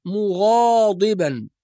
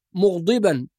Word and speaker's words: مغاضبا: كذلك في كلمة مغاضبا، بعض الناس لا ينتبه إلى الألف الصغيرة بعد حرف الغين، فبعضهم يقرأ مغضبا أو مغضبا، وهذا لحن جلي، وإنما ننتبه إلى فتح الغين